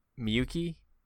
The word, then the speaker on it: Miyuki: Miyuki? – Yeah, that's right. – Okay. You said it a lot right, better Albert? Than I did. – Let me double check the spelling. – But Miyuki,